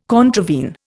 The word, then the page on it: contravene: Indian